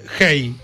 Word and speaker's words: Hay: En el año 2007 participé de un festival literario en Cartagena de Indias, Colombia, que se llamaba Hey, el festival. Se escribe Hay, H-A-I.